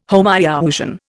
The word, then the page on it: Homoiousian: Indian